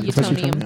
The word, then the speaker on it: Utonium: From the people that you guys were showing me pictures of, Utonium, is that how you say it? – Utonium? – Yeah, yeah. Utonium.